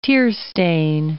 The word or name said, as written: tearstain